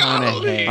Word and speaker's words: McConaughey: I'm friends with Matthew McConaughey. What's his name? I'm saying it wrong. Matthew McConaughey.